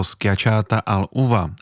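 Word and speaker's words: schiacciata all'uva: A kdyby se chtěl někdo pokusit o výslovnost, tak je to schiacciata al uva.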